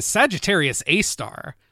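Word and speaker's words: Sagittarius A*: Well, star number three, sad to say, is Sagittarius A-Star. Which it's pronounced a star, but it actually refers to the supermassive black hole at the center of the Milky Way galaxy.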